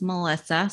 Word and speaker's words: Meliza: So yes, my name's Melissa, spelled with a Z.